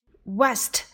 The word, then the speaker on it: west: 西方 west west。这个词跟我们那个三年级下册的马甲啊，它可发音不一样啊。马甲是要发 v v，轻轻的咬住你的下唇。 Vest，这个呢是 w 要发 w 的音。